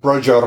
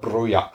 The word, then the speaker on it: Broja: It's it, Broja rhymes with Roger, not Broja.